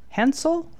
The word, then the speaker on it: Hensel: And so we do have a chart of Abigail and Brittany Hensel. Is that how it's pronounced? Yes. Yeah.